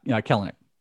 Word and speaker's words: Kelenic: So we just did our first Roto mock for 2021, obviously redraft Roto mock, and Jared Kelnick, is that how you say it? The Mariners. – Yeah, Kelnick. – It looks like Kelnick, but it's Kelnick.